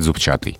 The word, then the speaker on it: зубчатый: Ну вот словари самые строгие по-прежнему настаивают на том, что надо говорить зубчатый. А в других словарях можно встретить и зубчатый.